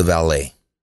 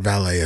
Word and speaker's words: valet: So how would you pronounce V-A-L-E-T? – The valet. – I pronounce it valet as well. And it is pronounced valet. The T is definitely silent.